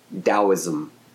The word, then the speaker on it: Tao: It's either spelled D-A-O or T-A-O. But even when it's spelled T-A-O, it's pronounced Tao. Taoism.